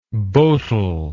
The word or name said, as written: bowtel